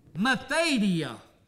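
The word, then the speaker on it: methadia (μεθοδεία): That one word, wiles, in the Greek is pronounced like this, methadia.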